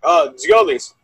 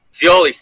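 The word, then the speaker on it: Giannoulis: You could pronounce that one there. – Giannulli. – The TZ? – Giolis. – Giolis, thank you.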